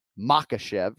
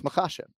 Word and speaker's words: Makhachev: So we're emphasizing the second syllable, right? So it's not Makhachev, right? It's Makhachev, right?